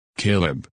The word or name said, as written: Caleb